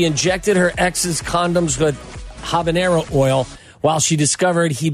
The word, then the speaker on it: habanero: Is that how you say it? Habanero oil? – Habanero, yeah.